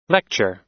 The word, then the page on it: lecture: American English